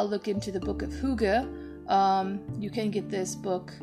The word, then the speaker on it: hygge: First off, the art of, I used to call it hygge. But it's actually hygge in Danish, so apologies to my Danish listeners. I'm sure you all have had a good laugh at me mispronouncing Danish.